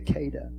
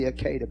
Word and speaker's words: Akedah: I believe it's pronounced the Akedah. I might be a little bit off on the pronunciation. The Akedah.